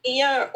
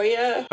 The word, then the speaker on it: Oia: It's like Ia or Oia.